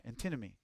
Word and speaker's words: antinomy: A-N-T-I-M-O-N-Y. Antinomy. A-N-T-I-M-O-N-Y. Antimony. Antimony. I don't know how you say it. I say it antinomy.